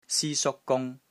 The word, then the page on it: SI1 SUK1 GUNG1: Cantonese